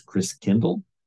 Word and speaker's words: Christkindl: The German pronunciation of Christ child is Christkindl, which is where you get the word Kindercare, kindergarten. Kind means child. And Chris meaning Christ. And over the years, Chris Kindle got pronounced Chris Kringle.